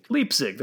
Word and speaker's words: Leipzig: Am I saying it right? Hopefully. – Leipzig, there you go.